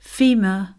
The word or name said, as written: femur